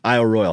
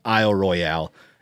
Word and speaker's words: Isle Royale: Isle Royal. He said that the James Bond movie Casino Royale was the bane for Rangers at Isle Royal, who then always had to hear it pronounced afterwards as Isle Royale, as I did.